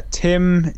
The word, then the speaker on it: Tym: So, A few years ago, apparently, he hooked up with, I think they're called Tim Pedals, T-Y-M. Is that right? Is it pronounced Tim? – Tim, yeah, because the guy, T-Y-M. – His name is Tim, isn't it? So yeah, it's Tim, but T-I-M.